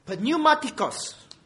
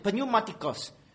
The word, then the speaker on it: Pneumatikos: Pneumatikos. Right, that's how you pronounce it. Pneumatikos.